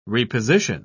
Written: reposition

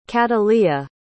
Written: kataleya

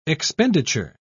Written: expenditure

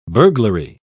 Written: burglary

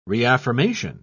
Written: reaffirmation

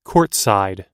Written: courtside

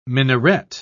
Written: minaret

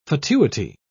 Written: fatuity

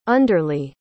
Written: underly

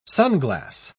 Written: sunglass

